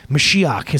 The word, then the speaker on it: Mashiach: Mashiach is how they say it in Hebrew.